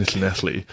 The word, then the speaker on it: Llanelli: And like, I just remember Googling the town, and it's like, it's in Llanelli, which is, I only know how to pronounce it now because my wife is Welsh and double L makes that sound.